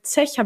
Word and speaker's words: Szech: Zech. Habe ich richtig ausgesprochen, oder Manuel? Richtig ausgesprochen, jawohl.